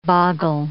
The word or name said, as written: boggle